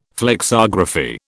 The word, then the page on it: flexography: British English